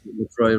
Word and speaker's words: LaCroix: First of all, is it LaCroix? I thought it was LaCroix. That's what I say. – Well, I think the idea is it rhymes with enjoy is what they say. Oh. But LaCroix rhymes with enjoy.